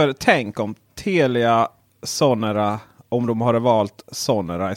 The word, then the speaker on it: Sonera: Jag försökte uttala det finska här men eh, eller det är kanske mer Sonera. – Ja det är nog mer ja, Sonera. Sonera.